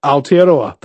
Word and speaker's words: Aotearoa: You know, that's how you pronounce New Zealand in Maori, Aotearoa, the land of the long white cloud.